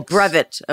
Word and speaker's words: brevet: Oh, brevet. Okay. – And that's a weird word. And what it is in the Sea organization. – Did I say it wrong? – No.